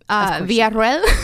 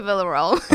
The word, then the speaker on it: Villarroel: How do you pronounce that? I mean, for 3 years I've been saying that. – Okay, Ronnie did it perfectly. Villarroel. – Villarroel? – But you can just say Villarroel.